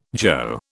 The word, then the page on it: jo: British English